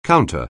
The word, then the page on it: counter: anglais